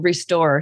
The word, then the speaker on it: Restore: So for your Lenten journey, I've written a book with Ave Maria Press called Restore. So it rhymes with the door, and that's on purpose.